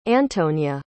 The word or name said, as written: antonya